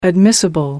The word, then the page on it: admissible: American English